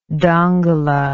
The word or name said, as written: dongola